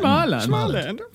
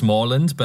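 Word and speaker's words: Småland: Smileland. – It's actually pronounced Smallland, but I thought that you wouldn't know what it was.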